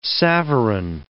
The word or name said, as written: savarin